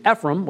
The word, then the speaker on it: Ephraim: Ephraim. I might prefer the pronunciation Ephraim, which is just kind of an American quick way of saying it.